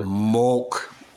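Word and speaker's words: Mülk: I don't know how you say it. Milk, molt, whatever. – Mulk. – Mulk. Good. Mulk.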